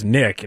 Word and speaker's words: Nick: You know, my name is Nick, and lots of things rhyme with that. – Oh, yeah. – So. – Like Slick.